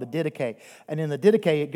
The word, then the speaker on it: Didache: It's called the Didache. I didn't know that's how you pronounce it for the longest time. I thought it was called the Didache, and I made myself like an idiot when I would say that.